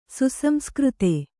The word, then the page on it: ಸುಸಂಸ್ಕøತ: suSamskřta